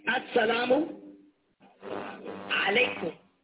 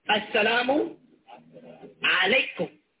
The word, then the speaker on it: As-salamu alaykum: And I know I meet a lot of people who would like to say this properly and they say it wrong. But I want you all to repeat it. Ready? Say, As-salamu alaykum.